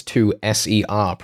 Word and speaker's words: 2SER: The way that I met happened to be virtually by sheer chance where you were working at TUSA on the podcast Get Gig Ready. – The corporate overlords are going to have to correct you. It's 2SER, pronounced one letter at a time.